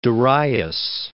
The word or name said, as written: Darius